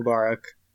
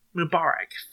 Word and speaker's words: Mubarak: Mubarak, how how the fuck do you pronounce that? Mubarak. – Mubarak, thank you.